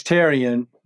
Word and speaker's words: Therrien: You can drop the H. It's Therrien.